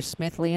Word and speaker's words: Smithly: It's a weird spelling. I have to spell it. And so the name is Smithley, and that is Smithly. And I haven't found another Smithley in the world.